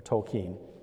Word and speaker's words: Tolkien: Tolkien, or Tolkien, depending on how you say it.